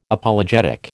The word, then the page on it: Apologetic: American English